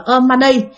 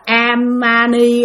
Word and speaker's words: Om Mani: Bạn không nhớ được ba chữ đầu là Om Mani, mà bạn cứ đọc là Amani Amani gì đó, là bạn đọc tùm lum hết trơn, không nhớ được.